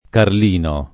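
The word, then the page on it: Carlino: italiano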